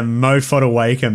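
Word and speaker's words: Moa Fotuaika: AJ Brimson, Moa Foeaker. Sorry for the pronunciations. – You've absolutely butchered that. Mo Fodawaker, mate.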